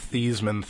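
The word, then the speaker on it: Theismann: Well, that's like the old Thiesman. – Right. – Because he wanted to rhyme with Heisman or whatever.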